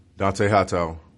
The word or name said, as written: Dont'a Hightower